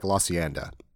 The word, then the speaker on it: La Cienega: And then have you ever noticed that if you're from L.A., you don't say it like I just said it, how it's spelled La Cienega? For whatever reason, if you're from L.A., it sounds like La Cienda.